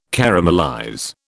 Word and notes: caramelize: British English

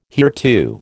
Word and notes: hereto: American English

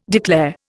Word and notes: declare: Indian

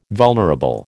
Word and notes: vulnerable: American English

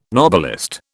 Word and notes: Nobelist: British English

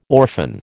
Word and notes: orphan: American English

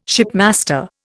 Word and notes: shipmaster: Indian

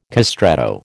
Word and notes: castrato: American English

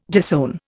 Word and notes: disown: Indian

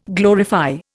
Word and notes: glorify: Indian

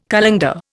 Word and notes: colander: Indian

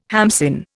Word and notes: hamsin: Indian

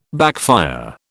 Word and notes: backfire: British English

